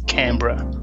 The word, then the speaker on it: Canberra: And pretty well pronounced, I've got to say. We probably say Canberra.